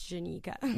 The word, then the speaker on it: Jenica: I wasn't going to correct you on radio, but guys, it's Jenica. – With a J. J-E-N-I-C-A.